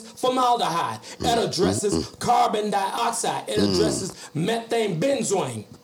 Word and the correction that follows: benzene: I hope I did benzene. I'm sorry. – Well, methane benzene. I pronounced that wrong.